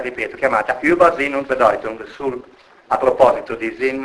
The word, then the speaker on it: Bedeutung: Questo è femminile, Allora, il nesso EU in tedesco si legge OI, mi dispiace per voi, ma... quindi Bedeutung, o Europa, in tedesco così dico. Bedeutung,